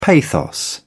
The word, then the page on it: pathos: British English